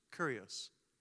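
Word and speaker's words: Kyrios (κύριος): that word Lord in the Greek means Kyrios. I think, is that how you say it? Kyrios. Kyrios. Thank you.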